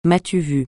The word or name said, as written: m'as-tu-vu